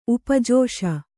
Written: ಉಪಜೋಷ

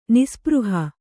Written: ನಿಸ್ಸ್ಪೃಹ

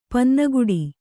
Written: ಪನ್ನಗುಡಿ

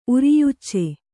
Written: ಉರಿಯುಚ್ಚೆ

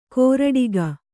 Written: ಕೋರಡಿಗ

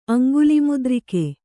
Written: ಅಂಗುಲಿಮುದ್ರಿಕೆ

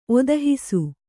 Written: ಒದಹಿಸು

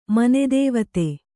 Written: ಮನೆದೇವತೆ

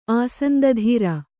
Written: ಆಸಂದಧೀರ

